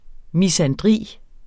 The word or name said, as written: misandri